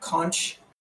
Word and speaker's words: conch: I'm shocked at how many people pronounce that conch since starting this podcast. I grew up in Florida, and it's conch.